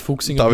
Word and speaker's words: Fuxinger: Nein, ähm, aber David Fuchsinger wird es. David Fuchsinger wird's.